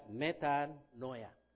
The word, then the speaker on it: metanoia: But you know, it is called metanoia, pronounced as metanoia. That's the word in Greek for repentance.